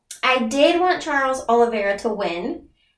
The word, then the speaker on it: Oliveira: Charles Oliveira.